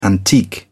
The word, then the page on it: antique: British English